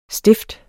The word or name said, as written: stift